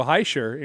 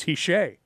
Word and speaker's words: Hischier: I got Nolan Patrick's name right, but I thought for all year long it was Nico Hischier, and here I hear it's Hiche.